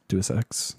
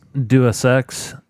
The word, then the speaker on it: Deus Ex: Please just Deus Ex. – Deus Ex.